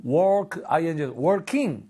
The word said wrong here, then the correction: walking: Walking, walking.